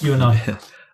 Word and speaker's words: UENI: And you've recently moved on from Paybreak and joined, if I pronounce this correctly, is it UENI? – Yes, UENI.